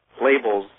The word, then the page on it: labels: American English